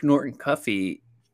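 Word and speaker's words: Norton-Cuffy: I'll be interested to see Brooke Norton Cuffey. I think that's how you say his name. – Yes, I looked it up like 2 minutes ago because he was my answer to this.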